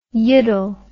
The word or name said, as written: yiddle